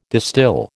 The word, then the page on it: distil: American English